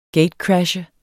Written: gatecrasher

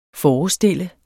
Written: forestille

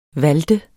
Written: valte

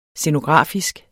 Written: scenografisk

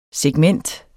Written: segment

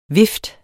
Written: vift

